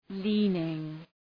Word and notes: leaning: ‘li:nıŋ

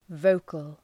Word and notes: vocal: ‘vəʋkəl

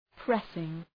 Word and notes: pressing: ‘presıŋ

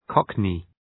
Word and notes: cockney: 'kɒknı

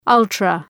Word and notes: ultra-: ‘ʌltrə

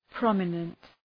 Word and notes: prominent: ‘prɒmənənt

